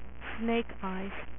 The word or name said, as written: snake eyes